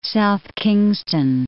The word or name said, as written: South Kingstown